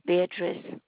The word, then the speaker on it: Beatrice: My name is Beatrice Woodruff. And you want me to spell that? – Yes, please. – All right. B-E-A.